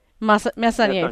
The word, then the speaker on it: Messonnier: And there's a scientist, a doctor, who speaks out publicly, Nancy, I'm not sure how to pronounce her last name, Messonnier? – Messonnier, thank you.